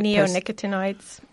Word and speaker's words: neonicotinoids: Neonicotinoids.